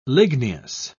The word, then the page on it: ligneous: British English